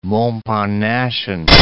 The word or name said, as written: Montparnassian